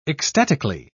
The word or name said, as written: ecstatically